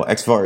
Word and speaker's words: expvar: Is it EXPVAR? Is that how you pronounce it? – Yeah, well, XVAR is one of the XVAR. Okay.